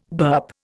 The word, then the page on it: burp: Indian